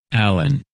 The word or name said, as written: Allen